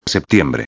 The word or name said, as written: septiembre